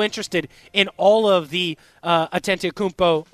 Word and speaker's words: Antetokounmpo: After I watched the movie, I've been pronouncing his name incorrectly. It's Giannis Antetokounmpo, and